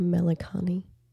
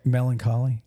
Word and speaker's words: melancholy: Sulliness or melancholy. – Really interesting. – I can't pronounce it right now. – That's melancholy. – Yes, thank you.